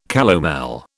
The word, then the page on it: calomel: British English